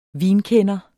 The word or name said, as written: VINKENDERE